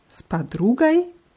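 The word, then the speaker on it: Spadrugai (с подругой): Bei spadrugai haben wir die Betonung auf dem u, deswegen wird oi so ein bisschen wie ei ausgesprochen.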